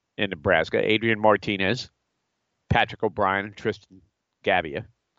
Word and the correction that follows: Gebbia: Gebbia, excuse me. I don't want to mispronounce his name.